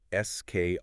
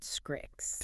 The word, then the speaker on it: SKRIXX: We're exploring a platform called SKRIXX. And just a quick note for you listening, it's spelled SKRIXX, but it's pronounced Skrix. – Right, Scrix.